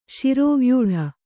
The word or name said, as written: ಶಿರೋವ್ಯೂಢ